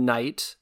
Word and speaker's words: Knight: Next thing we have is an A24 movie called The Green Knight, Knight spelled with that silent K.